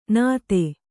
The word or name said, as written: ನಾದು